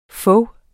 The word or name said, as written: fog